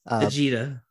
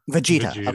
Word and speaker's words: Vegeta: Vegeta. – Vegeta.